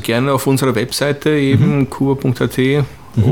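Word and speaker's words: kouba.at: Okay, also noch einmal die Webseite kuba.at, das schreibt man mit OU, also K-O-U-B-A, also kuba.at.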